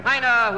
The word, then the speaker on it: Heine: A German poet whose name rhymes with a blackbird that talks. – Magpie or raven? – Craven? – No. – Heinrich Heine, whose name rhymes with Mina.